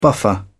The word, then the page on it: buffer: British English